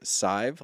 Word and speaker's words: Sadhbh: And she, her name is Sive, like five, but with an S. – Okay. – Because it's spelled S-A-D-H-B-H.